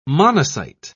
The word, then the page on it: monocyte: British English